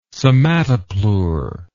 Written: somatopleure

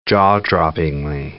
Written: jaw-droppingly

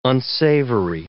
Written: unsavory

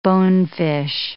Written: bonefish

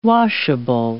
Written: washable

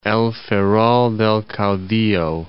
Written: El Ferrol del Caudillo

